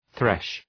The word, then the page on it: thresh: ɵreʃ